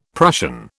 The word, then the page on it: Prussian: British English